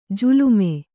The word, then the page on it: ಜಾಲೀಮ: jālīma; Kannada